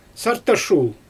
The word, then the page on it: sartashul: Xyzyl